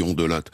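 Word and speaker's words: Hondelatte: Ah, je me suis longtemps battu pour qu'on prononce les syllabes, puisque moi je viens du sud-ouest de Bayonne, et donc chez moi on prononce toutes les... Donc on dit Ondelat.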